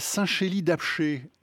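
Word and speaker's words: Saint-Chély-d'Apcher: Magali, vous habitez à Saint-Chély-d'Apché. Est-ce que je le prononce bien ? – C'est tout à fait ça.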